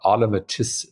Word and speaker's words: automaticity: So can you spend some time on how you think about automaticity? I can't say it. – Automaticity. I'm only saying it right because I've had to say it a lot. It's auto, like on autopilot, maticity.